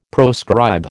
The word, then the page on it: proscribe: American English